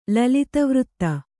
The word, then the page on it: ಲಲಿತವೃತ್ತ: Kannada